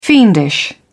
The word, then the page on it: fiendish: British English